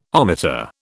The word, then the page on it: ohmmeter: British English